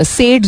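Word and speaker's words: Sade: सेड जिनका नाम लिखा जाता था एंड लोग इनको डिफरेंट तरीकों से प्रोनाउंस करते हैं साडे या सेड हाउ एवर यू वॉन्ट प्रोनाउंस इट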